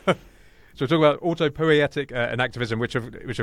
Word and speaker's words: autopoietic: now we have to be very careful how we pronounce this word. It's autopoietic. Is that right, Maxwell? – That's right.